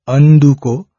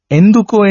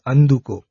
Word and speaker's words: Anduko: So one must be very careful of pronunciation also here. It's Anduko.